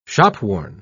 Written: shopworn

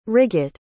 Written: riggot

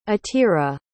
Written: atira

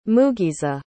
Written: mugisa

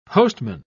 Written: postman

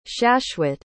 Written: shashwat

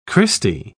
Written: christy

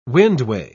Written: windway

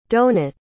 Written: donat

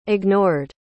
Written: ignored

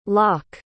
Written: lak

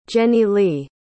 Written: jennilee

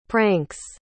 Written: pranks

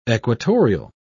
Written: equatorial

